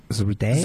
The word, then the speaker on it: Zarude: People are pronouncing it Zarud. – Been released. Like, how do you get it? It looks like Zaruda. – Zaruda. It's Z-A-R-U-D-E. – Zarude? – Zarude. That's how I look at it.